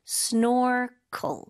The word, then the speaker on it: snorkel: 네, 영어로는 이음절로 발음해요. snorkel. 마지막 cl, 짧게. snorkel.